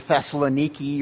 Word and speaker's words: Thessaloniki: pronounced either Thessaloniki or Thessalonica.